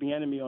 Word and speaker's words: Bieniemy: You said his name right there, Eric Bieniemy on the offensive side of the ball.